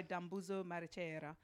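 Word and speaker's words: Dambudzo Marechera: You picked House of Hunger by Dambuzo Marechera.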